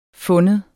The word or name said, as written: findes